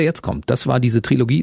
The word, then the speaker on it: Trilogie: Also wir hören einen Trilogie, dieser drei Songs. – Trilogie. – Was habe ich gesagt? – Trilogie. Ich bin Klugscheißer, schreibt man mit K und der Trilogie lässt man das O weg.